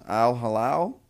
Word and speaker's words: Al Hilal: So the Saudi Arabian club, forgive me if I pronounce this wrong, Al Hilal, I believe that's it.